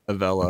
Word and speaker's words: Avello: Avello. – I'm pretty sure that's how you say it. Okay. A-V-E-L-L-O.